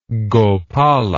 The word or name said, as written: gopala